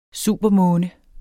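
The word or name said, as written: supermåne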